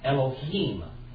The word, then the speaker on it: Elohim: Elohim.